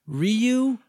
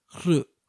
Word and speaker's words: Ryu: And even Ryu, we just accept that. Because really the way it's pronounced is Hru. But no one wants to say that because that sounds pretentious.